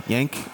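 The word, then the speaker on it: Gent: Yank. – Is that how you say it? That's not how I say it. – The G is kind of like a rolling Yank.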